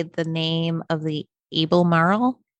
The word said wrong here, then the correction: Albemarle: Museum, the Abley Marley. – Yeah. Send us a recording of, she called them. She sent me a recording of their voicemail message. They said it right. I was cracking up. Oh my goodness. – Oh, okay. So it's actually pronounced the Albemarle. Oh, she's going to play it for us. – The Museum of the Albemarle.